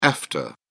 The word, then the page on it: efta: British English